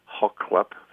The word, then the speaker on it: Xaxli’p: Is from Xaxli’p First Nations,